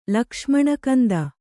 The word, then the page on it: ಲಕ್ಷ್ಮಣಕಂದ: kannada